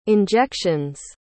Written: injection's

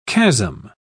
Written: chasm

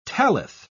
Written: tallith

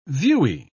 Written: viewy

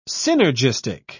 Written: synergistic

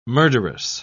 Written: murderous

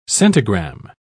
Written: centigram

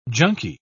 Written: junky

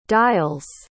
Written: dials

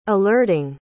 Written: alerting

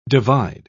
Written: divide

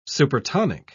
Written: supertonic

